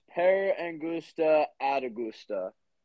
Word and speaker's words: per angusta ad augusta: It's pronounced per angusta ad augusta.